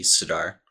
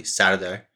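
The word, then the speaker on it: Serdar: Well, the American way would be Sirdar. – Sirdar. – But Turkish, it would be probably Sirdar.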